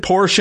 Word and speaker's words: Porsche: I mean, it is a two-syllable word if you go with the German pronunciation, and if you listen to the folks from Germany, they always say Porsche and the Porsche family and all that. So, I mean, if you prefer the one-syllable version, I don't necessarily have a problem with it. But to be correct, Porsche is the correct pronunciation.